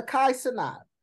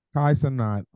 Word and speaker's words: Kai Cenat: Brother Kai Cenat. – Kai Cenat.